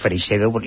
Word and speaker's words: Freixedo: Ángel Bastida Freixedo, que esto no se dice nunca, que su segundo apellido está castellanizado, es Freixedo, y era sobrino de Salvador Freixedo, también es casualidad.